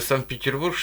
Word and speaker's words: Санкт-Петербург: Да, причем из одноименного города Санкт-Петербург, что во Флориде. Ударение правильно так.